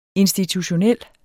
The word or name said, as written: institutionel